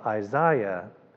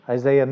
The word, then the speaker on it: Isaiah: We are continuing our study here this evening in the book of Isaiah, and I discovered the pronunciation of Isaiah. I was listening to a British theologian, and that's the British pronunciation. But Isaiah, we're here in Texas.